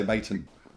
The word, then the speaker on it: Maton: I told at the NAMM show, I was playing Tommy's Maton guitar. – Yep. – I hope I'm saying that right. – Yeah. We say Maton.